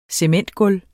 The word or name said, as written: cementgulv